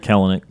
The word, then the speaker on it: Kelenic: I like the move at left field with Kelenic, you said? – Jared Kelenic. – Kelenic. Sorry, just learning to pronounce names.